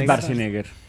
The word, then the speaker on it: Schwarzenegger: Es Schwarzenegger. – ¿Schwarzenegger? – Para nosotros nos enseñaron. – Es Schwarzenegger.